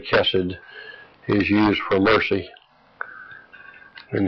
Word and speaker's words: Chesed: Chesed, of course, is spelled C-H-E-C-E-D and pronounced like K-H-E-D, the exclamation being on the first part of it. So that we have Chesed, S-E-D. The emphasis upon Chesed at the beginning. All right, the two, it is often coupled with emet, E-M-E-T, for truth when it is used.